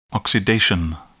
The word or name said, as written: oxidation